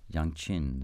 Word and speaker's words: yangqin: Ren, thank you very much, on Arhu and Yangqin. Did I say that right, more or less? – Very good, yeah.